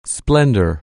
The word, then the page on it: splendor: anglais